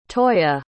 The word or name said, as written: toyah